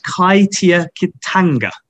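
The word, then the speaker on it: kaitiakitanga: And it's pronounced Kaitia Kitanga in Maori, which means it's the notion of guardianship.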